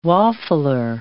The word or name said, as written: waffler